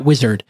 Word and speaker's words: Uizard: So let me tell you about Wizard. It's spelled UIZARD.